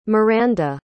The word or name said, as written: Myranda